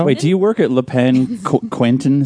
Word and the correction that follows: Quotidien: Is that how you pronounce it? – Yeah, Quotidien.